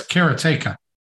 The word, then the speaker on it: Karateka: I don't know what the right pronunciation is on that one. – I believe it's Karateka.